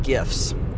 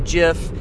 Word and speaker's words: GIF: And in this episode, I wanted to talk about animated GIFs. I know that there's some controversy over the pronunciation. You might pronounce it GIF, you might pronounce it JIF. You can pick.